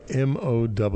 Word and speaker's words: haymow: Haymow, spelled just like the word mo, but this is mow.